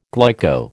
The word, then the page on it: glyco: American English